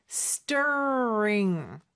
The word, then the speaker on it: stirring: Stirring. 자, 요 발음할 때 R 발음, 혀가 어디에도 닿으면 안 됩니다. 하지만 양쪽을 긴장시켜줘야 되겠죠? 그리고 ST의 T는 쌍디귿으로 발음하면 좀 더 편해요.